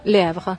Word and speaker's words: Le Havre: Le Havre.